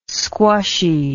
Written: squushy

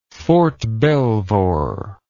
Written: Fort Belvoir

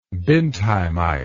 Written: bindheimite

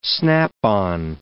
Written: snap-on

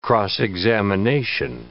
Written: cross-examination

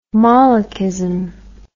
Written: molechism

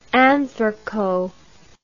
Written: anthraco

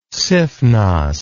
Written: sifnos

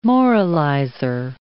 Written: moralizer

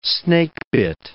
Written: snakebit

